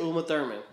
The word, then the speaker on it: Uma Thurman: Oh, speaking of which, Uma Thurman, how you say it? – Uma Thurman.